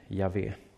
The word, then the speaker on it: Yahweh: När vi lägger till vokalerna så uttalar vi det Yahweh.